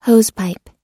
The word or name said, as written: hosepipe